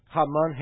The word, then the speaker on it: Haman: And in the Hebrew here, you have a play with the words Haman, Hema. The name Haman rhymes with Hema, that means anger.